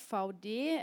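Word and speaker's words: Vaude: which is actually really pronounced VD, as I'm pronouncing it. It's derived from the initials of the company owner,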